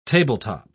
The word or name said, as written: tabletop